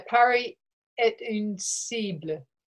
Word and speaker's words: Paris est une cible: Yeah, Paris est une cible. – That's right. Oh, yes. You, obviously, yeah. You speak French, unlike me. All right. Which is much more than me. Thank you for pronouncing it properly.